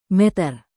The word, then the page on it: meter: Indonesian